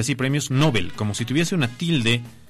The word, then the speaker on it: Nobel: A ver, los premios Nobel, en contexto te pongo un poquito.